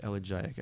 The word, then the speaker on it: elegiac: I think it's pronounced elegiac. Elegiac? Elegiac. As an elegy.